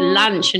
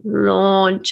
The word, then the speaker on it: launch: And so I actually took them through the exercise of like listening to the vowel sound. It's lunch and launch and, you know, like, cause they, yeah, when you see the way it's written, it doesn't help you at all.